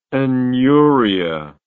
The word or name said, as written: aneuria